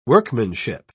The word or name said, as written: workmanship